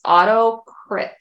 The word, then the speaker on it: AutoCrit: I believe that's how you pronounce it. It's AutoCrit.